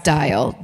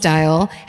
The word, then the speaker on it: Diehl: this is JT, I'm assuming it's pronounced Dial, D-I-E-H-L, 74.